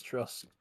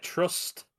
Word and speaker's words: Truss: I mean, people will argue that it was a political podcast from the moment we mentioned Liz Truss. – Probably, yeah, maybe. – I even said a name wrong. I said Trust.